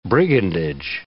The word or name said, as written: brigandage